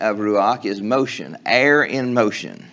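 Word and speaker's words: ruach (רוּחַ): And the Hebrew word, ruach, in our English is spelled R-U-A-C-H.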